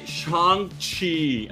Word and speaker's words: Shang-Chi: Shang-Chi. Así es que se pronuncia.